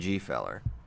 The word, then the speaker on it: Gfeller: St- uh, when we were talking about the harsh pronunciation, he's like, "Can we still say the G in Gfeller?" I think absolutely. I think we must.